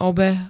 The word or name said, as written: obeh